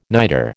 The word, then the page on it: nitre: American English